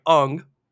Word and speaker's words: Aang: Night Shyamalan, the kid that they got for Aang. Sorry, Ung. – Don't just say it right. – The kid that they got for Aang, they literally casted him because he looked like Aang.